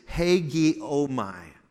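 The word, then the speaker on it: hagiomai (ἡγέομαι): The way you pronounce this Greek word is hagiomai.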